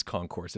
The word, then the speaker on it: Concourse: And for those, I can already see the emails, they're saying it's not Concourse. Well, it is Concourse. It is spelled like Concourse.